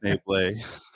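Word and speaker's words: Schnebly: That's and in School of Rock, he's just playing a guy named Dewey who's pretending to be Ned Schneebly. And that's fine. – Pronounced Schneeble.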